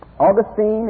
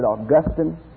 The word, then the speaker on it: Augustine: But Augustine is the way I pronounce his name. You may pronounce it Augustine.